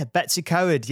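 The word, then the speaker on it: Betws-y-Coed: It was in Betsycoy. I think that's how that's pronounced. – Yeah, Betsycoed. Yes, it's a beautiful part of Wales.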